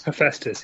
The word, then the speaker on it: Hephaestus: Hephaestus, yep.